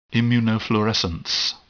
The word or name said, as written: immunofluorescence